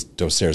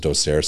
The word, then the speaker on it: docere: I think it's docere is the correct pronunciation. I've been saying it for a little over 20 years now as docere. So for me, it's docere.